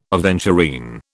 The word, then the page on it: aventurine: British English